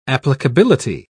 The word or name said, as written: applicability